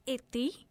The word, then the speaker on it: Iti: It's Ithi. Mm-hmm. It's pronounced, okay, it's spelled as I-T-I, but it is pronounced as I-T-H-I. So it's Ithi.